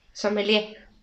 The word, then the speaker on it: sommelier: El sommelier, que soy yo, sommelier, así se pronuncia, sommelier. Se escribe sommelier, pero es sommelier.